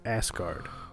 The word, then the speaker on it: Asgard: It's Asgard. It's pronounced Asgard.